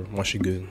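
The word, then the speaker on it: Moi Chuis Good: Oh yeah, Yo, so Yo Dollar, so Y-O space D-O-L-L-A, and Mushy Good is M-O-I space C-H-U-I-S space G-O-O-D for Mushy Good.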